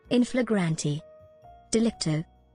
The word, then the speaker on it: in flagrante delicto: in flagrant delicto, in what the courts called it. – Scott, the correct pronunciation is in flagrante delicto, meaning caught in the very act of committing a misdeed.